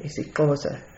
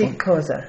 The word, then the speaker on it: isiXhosa: There are three languages in our valley predominantly, Afrikaans, English, and isiXhosa. – And so, sorry, the pronunciation of the last iSiXhosa. – One? iSiXhosa.